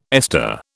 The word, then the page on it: ester: British English